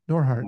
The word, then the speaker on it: Norhart: Yeah, Norhart.